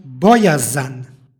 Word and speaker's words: bojazan: stihu izgovorili smo bojazan. Između krajnjih suglasnika umeće se u izgovoru takozvano nepostojano a.